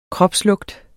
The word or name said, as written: kropslugt